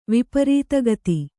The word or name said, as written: ವಿಪರೀತಗತಿ